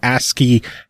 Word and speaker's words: ASCII: Yo, this game looks tight, kind of like ASCII visual speaking, or ASCII.